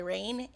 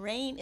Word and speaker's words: Rayne: My name is Dragonfly Rain, and Rain is spelled RAYNE.